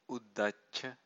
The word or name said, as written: uddacca